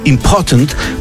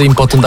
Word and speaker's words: impotent: Dobry akcent był, jeszcze raz powiedz, różnica między impotent a important. – Impotent. – Nie, to jest ważny, a important. To jest important.